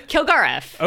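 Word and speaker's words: Kilgarriff: Kilgariff. – Okay. Is it like that, like a lot of letters? Is it actually phonetically? – I was figuring it out in my brain as I was saying it.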